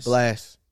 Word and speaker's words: Blxst: Blast. – Right, but it's spelled X. – Oh, B-L-X-S-T.